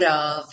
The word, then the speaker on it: of: Se você aprende get, aprende out, aprende of com F, né? Porque se você não souber que a pronúncia é sempre V, você vai estar pensando em F, porque é o que você lê, você vai estar esperando ouvir get out of this.